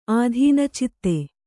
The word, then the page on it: ಆಧೀನಚಿತ್ತೆ: ādhīna citte